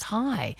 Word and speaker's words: hei: Brad in Woolloomooloo says, let's not forget that the Swedish word for hello is hey, H-E-J. Written H-E-J but pronounced hey, and the Norwegian word is hi, H-E-I, pronounced hi.